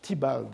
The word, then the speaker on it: Theobald: Et par qui a-t-elle été révisée et adaptée par euh, Mr. Theobald, je crois qu'on prononce Thibald euh, en anglais,